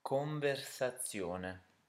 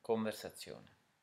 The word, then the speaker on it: conversazione: So, can you guess how to say conversation, thinking about turning the A sound into an A and the shun into zione? So, how would you say that? – Conversazione.